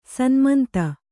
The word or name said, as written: ಸನ್ಮಂತ